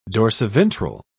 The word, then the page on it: dorsiventral: British English